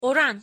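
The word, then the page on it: oran: Turkish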